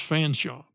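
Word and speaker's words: Featherstonehaugh: But and I've heard that it's supposed to be even pronounced Fanshaw, but it's spelled like Feather Stone Haugh, H-A-U-G-H.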